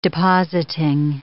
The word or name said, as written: depositing